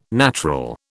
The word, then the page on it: natural: British English